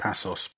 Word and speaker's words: Paços: Uh, Spurs, has predominantly played a 4 3 3, apart from uh, that back three in the first leg against Pajos. Have I mispronounced that? I think it's Paços.